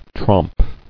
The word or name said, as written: Tromp